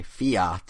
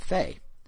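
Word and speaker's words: Fe: Nintendo called it Fei, but then in a Twitter video by the developers at Zoink, they all pronounced it Fia. So I don't know where the awe comes from, but it's apparently Fia.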